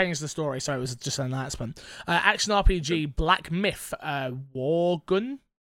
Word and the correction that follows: Wukong: Did I say that right? Wugun? Revealed. This one was taken from IGN Joe Screbbles. Black Myth Wargun. Wargun? Wargun? – Wukong. – Wukong? Sorry, say that one more time. – Wukong.